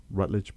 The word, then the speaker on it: Routledge: Before we jump into what I think is a very, very important work, Disability as Diversity in Higher Education, published by Routledge Press. Did I say that correctly? Very good.